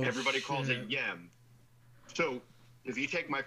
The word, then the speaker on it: YEM: Yeah, it's called Yem, but it's Yem because it's You Enjoy Myself.